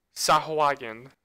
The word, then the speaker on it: Sahuagin: And if you know anything about, if you know like anything about the D&D Beast story, Sakahui? – Sahuagin. That's how I pronounce it.